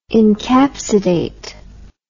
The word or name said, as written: encapsidation